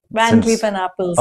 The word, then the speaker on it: Van Cleef & Arpels: Of course, Van Cleef Arpels, I hope my pronunciation is correct. – Van Cleef and Arpels, okay. No, it's correct.